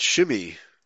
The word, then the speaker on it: Shimei: No, they just pronounce it Shimei. Shimei, okay.